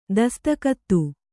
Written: ದಸ್ತಕತ್ತು

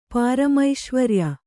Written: ಪಾರಮೈಶ್ವರ್ಯ

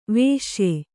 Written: ವೈಷಯ

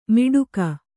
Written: ಮಿಡುಕ

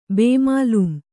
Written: ಬೇಮಾಲೂಮ್

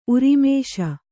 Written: ಊರ್ಮಿಸು

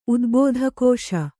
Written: ಉದ್ಬೋಧಕೋಶ

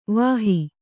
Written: ವಾಹಿ

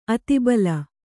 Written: ಆತ್ತಬಳ

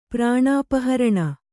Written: ಪ್ರಾಣಾಪಹರಣ

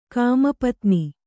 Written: ಕಾಮಪತ್ನಿ

